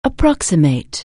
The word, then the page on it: approximate: anglais